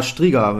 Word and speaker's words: Striga: Striga. Ja, kannst du das aussprechen? Du kannst ja so ein bisschen. – Äh, ja, Striga wäre es.